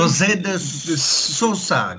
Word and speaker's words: José de Sosa: weißt du, was war es? – José war richtig. José de Sosa, glaube ich. Aber das A wurde gar nicht gesprochen.